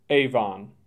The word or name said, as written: Avon